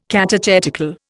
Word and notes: catechetical: Indian

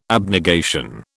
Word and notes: abnegation: British English

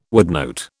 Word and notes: woodnote: British English

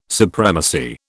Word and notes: supremacy: British English

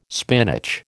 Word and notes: spinach: American English

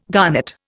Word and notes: garnet: Indian

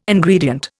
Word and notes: Ingredient: Indian

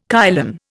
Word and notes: kylin: Indian

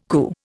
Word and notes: Ku: Indian

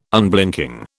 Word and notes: Unblinking: British English

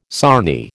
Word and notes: sarnie: American English

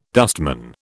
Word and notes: dustman: British English

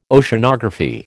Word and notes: Oceanography: American English

